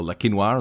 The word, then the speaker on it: Le Quesnoy: I mean, look back to our episode on Le Quesnoy, or La Quinoa, as we know it's pronounced.